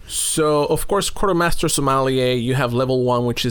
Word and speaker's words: sommelier: Ah, so you are a sommelier. Did I say it right? Sommelier? – That is correct.